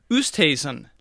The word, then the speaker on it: Oosthuizen: Oosthuizen.